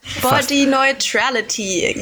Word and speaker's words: Body Neutrality: Ich hatte von euch, ein neues Wort gelernt in der Vorbereitung, nämlich Body Neutrality. Habe ich es richtig gesagt? – Body Neutrality, ja.